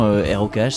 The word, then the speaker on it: Rokh: Et du coup, ça tombait bien parce qu'effectivement, ROKH, ça se prononce comme le genre de musique rock, donc on s'est dit que ça allait faire jaser.